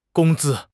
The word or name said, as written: gong1 zi1